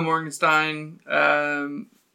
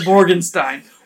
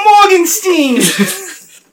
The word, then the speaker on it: Mortensen: No, it's not. Mortensen. Hold on, I have to pull it up. – There's no T in the end.